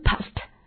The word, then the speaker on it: passed: 注意在清辅音后面加 ed 读作 t， past 表示经过的意思。 OK， for example，半小时过去了， half an hour passed， half an hour passed。